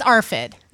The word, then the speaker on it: ARFID: AFRID or, you know, that thing. – Yeah, can we learn how to say it? – No. – I think it's ARFID. Rebecca's looking up. It's ARFID.